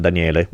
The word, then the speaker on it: Daniele: If you use in your order form, if you use the code just my name, Daniele,